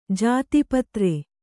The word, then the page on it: ಜಾತೀಪತ್ರ: jāti patra